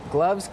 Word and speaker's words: gloves: ดเวลาค่ะไม่เป็นไรนะคะเดี๋ยวเราไปดูกันดีกว่าค่ะว่ามีคำไหนบ้างที่เมื่อกี้เราข้ามไปนะคะคำว่า close แปลว่าอะไรรู้ไหมคะอะไรนะคะสะกดได้ไหมลูก S gloves Gloves can be tricky because the V sound in the middle, gloves. Also, it's important to hear the S at the end of plurals.